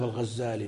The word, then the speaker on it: الغزالي: القاضي أبو بكر الباقلاني يضبطونه بالتخفيف بخلاف الغزالي بالتشديد، وهذا على خلاف المشهور، الناس ينطقونه بالعكس.